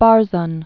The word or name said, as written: Barzun